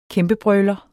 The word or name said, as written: kæmpebrøler